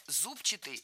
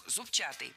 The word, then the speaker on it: зубчатый: Мы услышали прилагательное «зубчатый» с ударением на первом слоге, и это правильный вариант. Но правильным он стал совсем недавно. Раньше нужно было ударять второй слог «зубчатый».